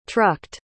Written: trucked

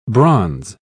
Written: bronze

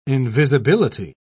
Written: invisibility

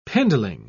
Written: pindling

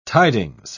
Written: tidings

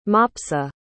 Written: Mopsa

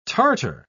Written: tartar

